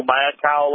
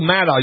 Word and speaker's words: Maddow: I don't know if you probably didn't watch Rachel Maddow last night. – Gosh darn it. Anyway, I meant to, and I forgot. I think you mean Rachel Maddow. You mispronounced her name on MSNBC.